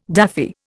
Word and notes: daffy: Indian

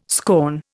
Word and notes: scorn: Indian

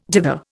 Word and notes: dither: Indian

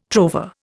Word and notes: trover: Indian

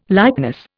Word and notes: likeness: Indian

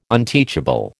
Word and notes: unteachable: American English

